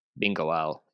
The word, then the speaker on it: Bingoal: I think he's going to Bingo. I'm not sure. – Bingoal is how it's pronounced.